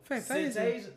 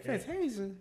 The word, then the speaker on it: Fantasia: Fantasia. – You can't even say her name right. – Fantasia.